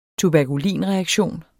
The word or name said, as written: tuberkulin